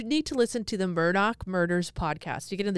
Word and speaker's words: Murdaugh: So it's Murdaugh, but they say Murdoch, like that's how they pronounce it.